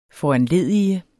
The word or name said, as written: foranledige